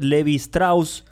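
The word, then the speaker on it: Lévi-Strauss: Voy a trabajar con un texto del antropólogo francés Claude Levi Strauss. Se pronuncia como los pantalones de jean Levi Strauss.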